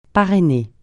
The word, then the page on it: parrainer: francais